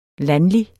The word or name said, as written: landlig